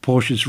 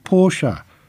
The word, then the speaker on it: Porsche: German speakers would also ensure they read the company name as Porsche, as opposed to the single syllable Porsche version.